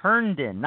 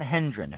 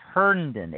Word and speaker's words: Herndon: And by the way, it's Chris Herndon, not Hendren. It's Herndon. H-E-R-N-D-O-N.